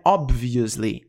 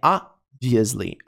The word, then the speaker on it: obviously: But, mas, we obviously, pronúncia não é obviously, é obviously, obviously, obviously, você não pronuncia o B.